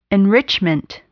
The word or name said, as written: enrichment